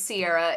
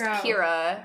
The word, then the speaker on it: Ciara: I just Googled C-I-A-R-A pronounced, and it says it's an Irish name, and it's pronounced Kira.